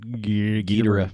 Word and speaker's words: Ghidorah: And I knew it was King and I never knew how to say it. – Ghidorah. – Ghidorah.